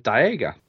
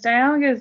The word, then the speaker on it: Dialga: Yeah, I went with Dialga. – Of course you went with Dialga. – Yeah. – There's an L in there, by the way. – Whatever. Fuck, silent L.